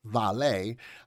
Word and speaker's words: Vale: Now, Vale, S.A., Portuguese pronunciation, Vale, a multinational corporation engaged in metals and minings and one of the largest logistic operators in Brazil.